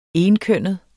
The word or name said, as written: enkønnet